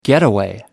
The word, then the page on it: getaway: American English